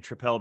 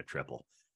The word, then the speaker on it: tripel: We found ourselves saying tripel because one of our other beer vendors is the your friendly neighborhood tripel man, and he insists on pronouncing it that way. So that got me trained to call it tripel. But I think there's nothing wrong with calling it a triple.